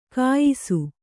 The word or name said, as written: ಘಯ್ಸ